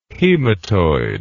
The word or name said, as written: hematoid